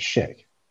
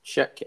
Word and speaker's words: Schick: I reckon shit. Oh, I'm saying that wrong, aren't I? Shit is Schick. – Schick, I think it's Schick.